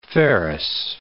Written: ferrous

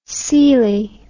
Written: coeli

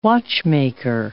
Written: watchmaker

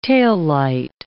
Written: taillight

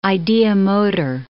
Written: ideomotor

